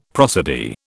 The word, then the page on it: prosody: British English